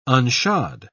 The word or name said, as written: unshod